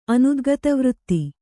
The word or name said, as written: ಅನುದ್ಗತವೃತ್ತಿ